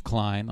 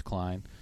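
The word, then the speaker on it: Kline: Vintage Klein on Twitter. People spell my last name wrong. It's K-L-I-N-E. Vintage Klein. Andy Klein on Instagram.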